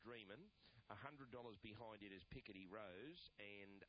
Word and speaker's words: Picardy: We've got Picardy Rose, that's the correct pronunciation. It's a town in France, Picardy.